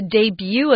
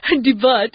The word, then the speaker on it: debut: When you talk about the debut of something, notice this is a strange word because we're using the French pronunciation. So if you look at this word, it's not debut. That's what it looks like. Debut. It's debut.